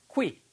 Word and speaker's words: quit: quitting. 발음이 Q 발음이요. Q, Q, quit. 퀴 이렇게 발음하셔야 돼요. 키링이 아니고 퀴. 퀴.